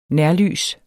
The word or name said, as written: nærlys